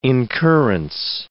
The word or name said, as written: incurrence